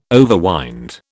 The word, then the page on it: overwind: British English